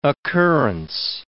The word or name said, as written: occurrence